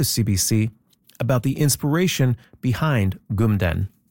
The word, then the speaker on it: kmtn: Gumden is spelled K-M-T-N. It means mountain in Mi'kmaq.